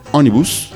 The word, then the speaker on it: ônibus: En portugués, en Brasil, se dice ônibus para referirse al bus, mientras que en Portugal es autocarro.